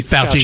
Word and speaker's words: Fauci: Fauci, yeah, was, say, oh, you don't really need it.